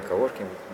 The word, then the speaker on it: коворкинг: Я вообще правильно произношу это слово? Все верно, коворкинг.